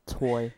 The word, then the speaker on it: Twohy: I don't know how to say his name. T-W-O-H-Y. Twohy. So it's just toy.